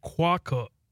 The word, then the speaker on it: quokka: Okay, so it's quokka. Quokka. – Okay. – Quokka. That's how you say it.